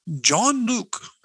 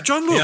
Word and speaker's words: Jean-Luc: Jean-Luc. Yeah, Jean-Luc. Yeah, Jean-Luc.